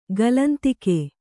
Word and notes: ಕಾಲಾಂತಕ: kannada

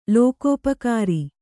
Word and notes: ಲೋಕೋಪಕಾರಿ: lokōpakāri

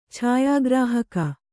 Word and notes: ಛಾಯಾಗ್ರಾಹಕ: chāyā grāhaka

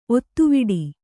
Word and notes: ಒತ್ತೆವಿಡಿ: otteviḍi

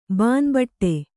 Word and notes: ಬಾನ್ಬಟ್ಟೆ: bānbaṭṭe

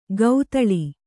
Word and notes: ಗೌತಳಿ: gautaḷi

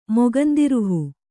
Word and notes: ಮೊಗಂದಿರುಹು: kannada

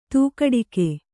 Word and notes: ಧಕ್ಕಡಿಗ: Kannada

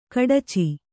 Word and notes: ಖಡಚಿ: khaḍaci

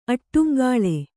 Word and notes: ಆಡುಂಗುಳಿ: āḍuŋguḷi

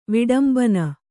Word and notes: ವಿಡಂಬನ: viḍambana

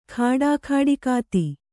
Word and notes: ಖಾಡಾಖಾಡಿಕಾತಿ: Kannada

